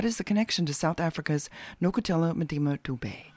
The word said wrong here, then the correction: Dube: I put the accent on the wrong part. Dube.